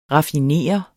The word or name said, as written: raffineret